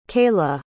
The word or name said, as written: kaylah